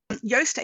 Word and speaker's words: Yoast: What is it, Yoast, or is it how you pronounce it? – So for Yoast SEO, so WordPress plugin, but they're also now available for Shopify.